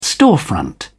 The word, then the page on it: storefront: British English